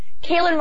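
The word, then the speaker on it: Caolan: Kaylin Robertson. So he is known as Kaylin Rob on Twitter. His name is spelled CAOLANROB.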